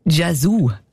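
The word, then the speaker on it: Yazoo: Und, der Song Don't Go von Vince Clarke und der Alison Moyet, alias Jesus. Ich habe mich immer gefragt, ob ich das richtig ausspreche oder nicht. Jesus. – Jesu. – Gell? Jesu. – Ja. – Das ist richtig.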